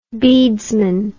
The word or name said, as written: beadesman